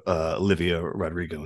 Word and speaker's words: Olivia Rodrigo: I'm going false only because I think Isaiah Pacheco should go for Olivia Rodrigo instead.